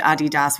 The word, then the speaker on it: Adidas: So just to clarify a little bit more about, and I know you said Adidas, which is the proper way to say it, but the Americans, we'll say Adidas, I think, for the most part.